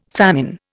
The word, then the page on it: famine: Indian